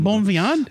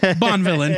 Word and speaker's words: Bonvillain: No, she said it sounds just like Bonvillain. – Oh, okay. Yeah, we were going like Bonvillain. – Bonvillain? No, Bonvillain.